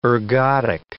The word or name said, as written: ergodic